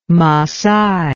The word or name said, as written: maasai